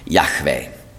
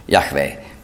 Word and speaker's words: Yahweh: Ich spreche ihn jetzt aus. Und zwar so, wie er ursprünglich ausgesprochen wurde. Wir wissen das, weil wir ganz frühe Transkriptionen haben mit griechischen Buchstaben. Da sind die Vokale dabei. Yahweh. Mit hörbarem H.